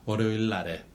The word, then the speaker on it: 월요일 날에: 월요일 날에. – 월요일 날에. – 그래서 그럴 때는 니은으로 안 하고 똑같이 리을로 발음해요.